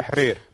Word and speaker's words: Tahrir: Tahrir. – Tahrir. Can I get a lesson in pronunciation? – You just pronounce it right.